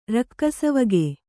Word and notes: ರಕ್ಕಸವಗೆ: rakkasavage

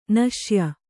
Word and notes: ನಾಸ್ಯೆ: kannada

